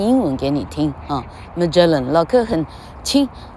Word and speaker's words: Magellan: 哦，呃，麦哲伦叫 Magellan 哈，Magellan 麦哲伦海峡吧哈，老客居然连麦哲伦怎么念都不会 Magellan 哈，Magellan，而且为什么说，啊，老客告诉 Angela Young 哈，移民纽西兰的，呃，台湾的小女生哈，啊，为什么是看着长片呢？